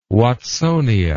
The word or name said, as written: watsonia